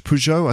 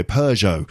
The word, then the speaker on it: Peugeot: In French, it's Citroën, and Peugeot, which I still find difficult to pronounce in French. Peugeot, I think it is. Anyway, Peugeot. So we were driving a Renault,